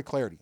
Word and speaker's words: Clardy: At Troy Clarity. Last name is spelled C-L-A-R-D-Y.